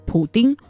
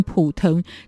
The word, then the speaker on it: 普京: 那说到俄罗斯长期以来的权力所在地，也就是弗拉迪米尔· 普丁、普廷。或者你要叫他普京，但其实发音更接近普腾。